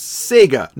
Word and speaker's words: Sega: that we've somehow never flagged up the strange way so many Americans say Sega. I mean, there's a little jingle there that gives you a pronunciation guide at the start of Sonic. – Sega. There's a bit of a clue, isn't there? – There's a little clue that it's Sega, not Sega. The Sega Genesis.